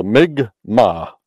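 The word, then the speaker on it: Mi'kmaq: We had to get that pronunciation right. So it's, you know, the mig ma.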